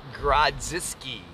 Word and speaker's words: Grodziskie: Graditsky. Would you like to pronounce it better? Grodziskie. Graziski. – He is Polish. There is a Z in there.